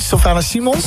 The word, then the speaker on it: Sylvana Simons: Als ik aan R&B denk ik eigenlijk gelijk aan, zeg ik dat goed, Sylvana Simons? – Ja, op TMF, hè, was dat?